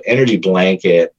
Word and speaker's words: ENRG Blanket: And so this, and it's spelled ENRG blanket. And so Energy Blanket, you know, stands on its own.